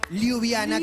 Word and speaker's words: Ljubljana: ¿Liviana? Ljubljana, que es la capital de Eslovenia.